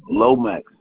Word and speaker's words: Lomax: D-W-I-G-H-T. – All right, and the last name? – Lomax, L-O-M-A-X.